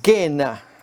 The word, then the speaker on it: Gehenna: La palabra que consideraremos a continuación es Gena. Esta es la forma griega de pronunciarlo, al valle de Ginnom, como lo tenemos allí escrito, que refleja un lugar que llegó a ser un quemadero.